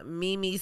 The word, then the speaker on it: Meme: My Instagram is I am Mimi Simpson. My name is spelled Mimi, like M-E-M-E, like Mimi, not U-U.